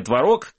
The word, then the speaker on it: творог: Эксперты Росконтроля проверили 9% творог, который, кстати, знаешь, что творог и творог, это же слова-варианты, они тебе очень нравятся.